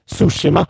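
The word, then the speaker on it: Tsushima: Och bara två pers har röstat på det, det uttalas Sushima, men det är faktiskt rätt.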